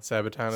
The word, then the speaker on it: Sabaton: Saying that correctly? – Sabaton is correct.